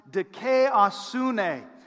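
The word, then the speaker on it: dikaiosune (δικαιοσύνη): This word for righteousness in the Greek is pronounced dikaiosune.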